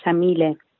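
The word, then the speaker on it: Yamile: Hello, my name is Shamile Daniela Sayed Mendez. Shamile is an Arabic name, and because I'm Argentine, I pronounce it with an Argentine accent, the Y like an SH, so Shamile.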